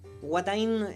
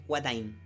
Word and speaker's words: Watain: Watain, eh, W-A-T-I. – Watain. Sí, Watain, sí. No como Wata con G.